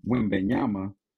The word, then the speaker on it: Wembanyama: Wembanyama, hope I'm saying his name right, Wembanyama, I think you said it right.